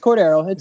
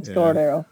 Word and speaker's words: Cordero: It's Cordero. Cordero.